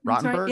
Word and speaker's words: Rotenberg: Did I say it right? – Rotenberg? Yeah. Okay. Rotenberg okay. is right. Yeah.